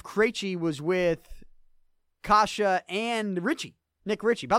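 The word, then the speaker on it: Kaše: Not Case, or Kase. Andre Kasha. I believe I'm saying that right. I'm trying to tell you how to pronounce it. Imagine I'm getting it wrong. No, it's Andre Kasha.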